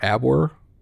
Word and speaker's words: Abwehr: How do I say that? A-B-W-E-H-R? Abwehr? – Oh, Abwehr. – Abwehr. Should have used a V, Germany. Anyway